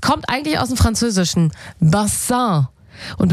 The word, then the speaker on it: Bassin: Also statt Bassin wurde es irgendwann Baseng.